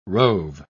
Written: rove

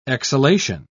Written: exhalation

